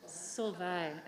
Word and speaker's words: Solvay: Now, am I saying that right? Solvay? – Solvay, is how we say it. – One more time. – Solvay. – Solvay. – Yes, you got it.